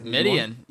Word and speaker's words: Midian: And he goes to the land of Midian. I want to pronounce it. I think that's right. Seems like Midian. Yes, sir. That's correct.